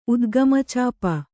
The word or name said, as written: ಉದ್ಗಮಚಾಪ